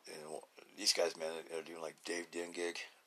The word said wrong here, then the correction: Kindig: you know. Oh, did I say that right? Kindig, that's it, Kindig.